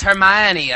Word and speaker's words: Hermione: It's Hermione, as in Gingold. – But you know what? I wouldn't have known that, I don't think, if I didn't know Hermione Gingold from my LP of...